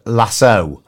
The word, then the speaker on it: Lasso: and it's pronounced Lasso.